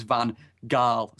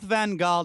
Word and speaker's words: Van Gaal: I just remember that when he did a press conference when he came, he said the English translation, the English pronunciation was Van Gaal. So you know what? Let's stick with that. – Let's stick with Van Gaal.